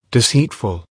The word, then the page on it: deceitful: American English